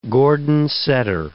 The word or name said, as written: Gordon setter